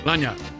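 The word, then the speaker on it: lagniappe: All the little langapes. Did I say that right? – Lanyap. – I always say langapes. See, Lanya. – All the little extras. – A little extra.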